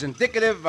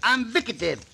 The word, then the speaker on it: indicative: Say a word by syllables, and it is very easy to pronounce. – Uh-huh. – In. – Dick. – Ative. Indicative. – Ambicative.